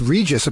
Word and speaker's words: Regis: Look, I couldn't figure out how to pronounce Regis, apparently, in 1991. – Yeah, Reggie Philbin? Well, I guess he wasn't a star then, at least not in my world. – New York treasure, Reggie Philbin? – No, the WBAI engineer let him know.